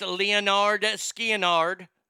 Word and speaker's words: Lynyrd Skynyrd: Number nine, Lynyrd Skynyrd, pronounced Leonard Skinnard.